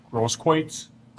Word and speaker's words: Grosskreutz: Mr. How do you say his last name? – Grosskreutz. – Grosskreutz? – Good enough.